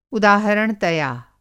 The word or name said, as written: उदाहरणतया